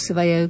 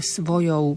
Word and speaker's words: svojou: Toto sú tie úžasné dve písmenká za sebou. Ou, ou. Musíme ich vysloviť presne, aby to nebolo svoje pravice, aby to bolo také niečo, taký hybrid medzi výslovnosťou E a U. Nie, to je svojou pravicou.